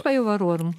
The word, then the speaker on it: Bajuvarorum: Ein wichtiges Schriftstück ist die Lex Bajuvarorum, wo spricht man das? Bajuvarorum, so ist es richtig? – Ja, Lex Bajuvarorum.